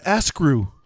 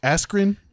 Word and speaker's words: Askren: He called him Askrew.